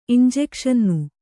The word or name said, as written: ಇಂಜೆಕ್ಷನ್ನು